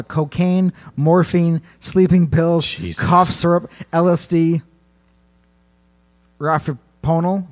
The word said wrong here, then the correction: Rohypnol: Is that how you pronounce that? – No idea. – I screwed it up. – Rohypnol? – Yeah, there you go.